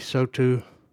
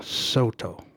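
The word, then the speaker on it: Saulteaux: At CFCR, we acknowledge that we are broadcasting from the traditional territory of the Cree, Dene Soto. I pronounced that wrong. Soto. There we go.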